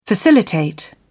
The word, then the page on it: facilitate: British English